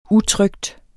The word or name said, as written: utrykt